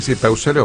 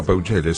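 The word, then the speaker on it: Peucelle: Sí, Peusele o Peuchele, sí, está bien.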